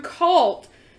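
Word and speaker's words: culte: So the term cult first appeared in English in 1617, and we got that from the French word, I'm gonna spell it because I don't know how to say it, it's c-u-l-t-e, Anybody here speak French? – Cult. – So it's still just cult. Awesome. And that, it's like cast and cast system, the C-A-S-T, it's the same.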